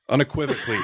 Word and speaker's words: unequivocally: Can you say equivocally? Did I say that right? – Unequivocally, Sunday Jeff.